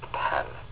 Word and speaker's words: pen: That would be the way it would be pronounced, pen.